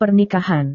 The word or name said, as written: pernikahan